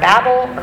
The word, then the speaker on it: Babel: We have the dispersion of nations in the Tower of Babel, or Babel, whichever your particular pronunciation preference is.